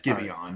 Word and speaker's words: Giveon: Yeah, it's Giveon.